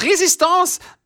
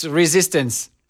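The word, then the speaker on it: Résistance: Zum einen heißt es Resistance, nicht Resistance. Sie müssen es französischer aussprechen. Dadurch wirkt das Ganze so schön dramatisch.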